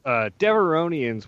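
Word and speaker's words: Devaronians: Where do we know that species from? – So Devoronians. – Devorian. I said it wrong, apparently.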